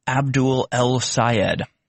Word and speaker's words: Abdul Al-Sayed: Michigan Senate candidate Abdul Al-Sayed said you cannot, if you can't pronounce his name, it's easy to pronounce, Abdul Al-Sayed.